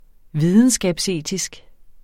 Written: videnskabsetisk

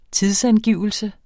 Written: tidsangivelse

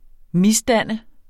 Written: misdanne

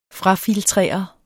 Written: frafiltrere

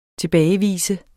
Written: TILBAGEVIS

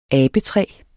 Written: abetræ

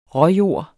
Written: råjord

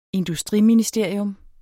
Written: industriministerium

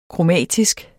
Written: kromatisk

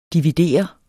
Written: dividere

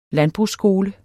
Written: landbrugsskole

